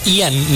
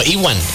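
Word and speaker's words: Ewan: No, o sea, pregunto, porque según yo era Ian, ¿no? Ian McGregor. – No, Iwan. – Iwan McGregor. – ¿Es Iwan? – Iwan McGregor. – ¿Así se pronuncia? – Ajá. – Okay. – O sea, E-W-I-N. – Ajá. Iwan McGregor. No es Ian.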